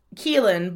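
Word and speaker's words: Caoimhín: Kaelin, It's pronounced Keelan, but it's spelled.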